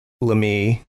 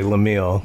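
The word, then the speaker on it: Laemmle: Young Hollywood producer Carl Lemie, I want to say Lemille because the way it's spelled.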